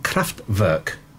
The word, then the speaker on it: Kraftwerk: is a band called Kraftwerk.